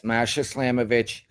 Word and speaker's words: Masha Slamovic: I believe it's the Friday night with GCW against Masha Slamovic. I'm not sure if I'm pronouncing her name right. – Yes, Masha Slamovic.